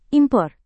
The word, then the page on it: impor: Indonesian